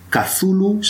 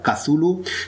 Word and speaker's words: Cthulhu: Si alguien quisiera articular en una fonética, digamos, común y procesable por distintos sistemas fonológicos, Cthulhu sería lo más convencional, lo más estandarizado. Con lo cual, de aquí en el futuro, si queréis utilizar una adaptación a los órganos humanos de cómo se debería pronunciar, Cthulhu sería la más ortodoxa.